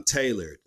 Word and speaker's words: Tedlored: Brand, black owned clothing brand called Tailored, but it's spelled T-E-D-L-O-R-E-D. Tailored.